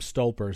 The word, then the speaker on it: Stolper: You know, we come back to, again, you caught this man, and I mispronounced his name, Stolper is his last name.